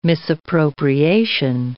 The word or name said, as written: misappropriation